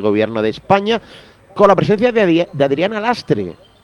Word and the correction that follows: Lastra: Sí, el Lastra. – Perdón, al final lo digo mal.